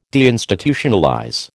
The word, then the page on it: deinstitutionalize: American English